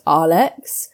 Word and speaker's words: Aalex: And the way that he says my name is pronounced Aalex.